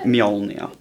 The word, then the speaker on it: Mjolnir: Mjolnir. Am I saying that right? – Mjolnir. – Mjolnir. – Yeah.